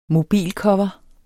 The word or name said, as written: mobilcover